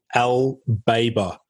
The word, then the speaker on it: BAIBA: So this particular chemical is called L-BABA. It's spelled, you know, it's a weird spelling molecule. It's obviously, it sounds weird when you pronounce it. It's spelled B-A-I-B-A.